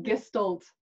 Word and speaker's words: Gestalt: Is that how I pronounced it properly? Gestalt? – Yes, you have. Gestalt.